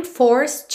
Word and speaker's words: forced: Tá no passado, cuidado para não dizer forced. Esse é não é pronunciado. O D poderia ser mais, menos ou nada. E como a próxima palavra é to, a gente já liga num som só.